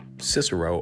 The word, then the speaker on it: Cicero: Cum cicerone, with Cicero or Cicero, uh, as uh, we know is the right way to pronounce Cicero.